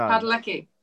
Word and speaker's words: Padalecki: You try and pronounce this. P A D A L E C K I. – Padalecki. – Palanaki. – Paladecki. No. – Padalecki. – Padalecki. See? All right. – No, we said it.